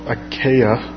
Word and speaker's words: Achaia: I've been trying to pronounce that word right all day long, Achaia, to go to Jerusalem, saying, after I have been there, I must also see Rome.